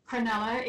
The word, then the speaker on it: Cronulla: Is that how you pronounce it, Cronulla? – Cronulla is the way you pronounce it.